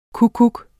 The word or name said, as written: kukkuk